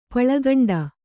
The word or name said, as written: ಪುಳ್ಕೊಂಡ